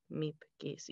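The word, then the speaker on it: Miep Gies: Meep Geese, yes, who played a critical role in hiding Anne Frank and her family during the Nazi occupation of Amsterdam.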